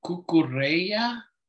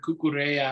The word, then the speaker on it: Cucurella: It's probably Cucurella. I guess the double L forms a Y.